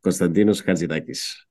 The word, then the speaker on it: Konstantinos Hatzidakis: Yes, as we would call him in Greek. Konstantinos Hatzidakis. Yes.